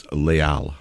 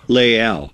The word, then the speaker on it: Les Halles: What's the correct pronunciation of the restaurant where you were the head chef in New York? Uh, it's Les Halles. Leal.